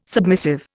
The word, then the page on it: submissive: Indian